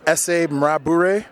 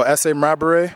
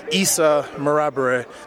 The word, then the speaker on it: S.A. Marabare: Okay, so it's properly pronounced S.A. Marabare. So S.A. Marabare. The worst I've heard... It's just recently from the TSN crew, Issa Marabare. They get the last name right, but the essay part, it's like I've heard like Issa, Issy, Issey.